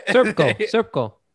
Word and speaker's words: Srpsko: It's spelled SRPSKO. All right. – Serpko. – Okay, there you go.